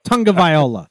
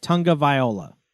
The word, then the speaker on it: Tagovailoa: Tagovailoa. There's no N in it, but that's how you say it. Tagovailoa.